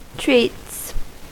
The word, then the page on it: treats: American English